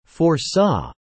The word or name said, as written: foresaw